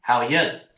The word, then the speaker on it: 好运: 好运. – 好运. 好 is good, just like 你好, 好 is good. And 运 is the fortune. – 好运.